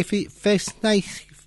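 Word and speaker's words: Verzeichnis: Y ahí viene Bach, Werke Festneich. Que sería la B corta pronunciada con F en alemán, mi alemán es cero.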